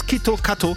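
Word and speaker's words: Kito Kato (キットカット): En japonais, Kit Kat se prononce Kito Kato, qui sonne presque comme Kito Katsu, une expression signifiant tu vas sûrement réussir ou tu vas y arriver.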